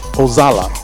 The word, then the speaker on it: Oxala: It's called Olaxa. O-X-A-L-A. We pronounce X as a Z, so maybe it's Ozala. Yeah, that's what it is.